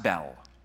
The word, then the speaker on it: Bel: But the second thing relates to another name for Marduk, another name that you'll find in the Bible, pronounced Bel. Not like Bell, like in Beauty and the Beast, all right?